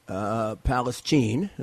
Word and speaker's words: Palestine: This is Rudy Giuliani, and I am talking to you from Canfield, Ohio, which is 15 minutes from East Palestine, Palestine is the right pronunciation here among the people here, Ohio.